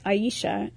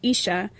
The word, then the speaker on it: Aisha: with three syllables, Aisha, instead of the two, Isha, that my parents had used since I was born.